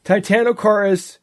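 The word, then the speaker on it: Titanokorys: The Cambrian species, how do you pronounce that, Steve? Titanocoris? – Yes. – To say Titanocoris. – Titanocoris, huge bug.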